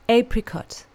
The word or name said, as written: apricot